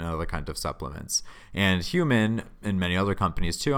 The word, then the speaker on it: HVMN: It's spelled with an all caps H-V-M-N, but pronounced Human.